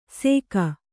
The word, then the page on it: ಸೇಕ: Kannada